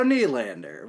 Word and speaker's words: Nylander: According to Hockey Reference, their last name is pronounced Nylander. – According to how to pronounce Nylander on YouTube, it's pronounced, wait for it, William Nylander. – There you go. – Thanks, robot lady. – So Nylander, with the knee, got it.